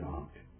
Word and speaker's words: aunt: Everyone needs a good aunt, and he had a good aunt. It is pronounced that way.